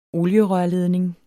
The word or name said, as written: olierørledning